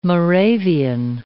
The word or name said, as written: Moravian